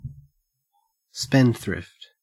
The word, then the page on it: spendthrift: Australian English